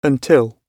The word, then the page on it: until: British English